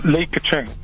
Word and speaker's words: Li Keqiang: Lee, Li Keqiang, Premier Li Keqiang is the, I think, the official pronunciation.